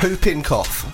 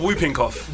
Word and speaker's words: whooping cough: So it's not whooping cough. – Whooping cough. – Yeah. The W silent. All this time I thought it was whooping cough.